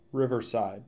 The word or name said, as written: Riverside